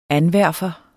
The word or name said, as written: anverfer